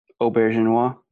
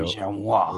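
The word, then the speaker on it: Auberjonois: And this week we're talking about, I can't pronounce his last name, so someone else do it. – Auberginois. – There you go. – Auberginois. – René. – Hey, with a French accent. – René Auberginois.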